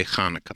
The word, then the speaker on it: Hanukkah: The way I grew up is the Ch. – Yeah. – And a lot of Gentiles have difficulties with Ch. So even though it could be spelled with a Ch, you'll have people say Hanukkah all the time. I say Hanukkah. – Okay, so there's a little guttural there.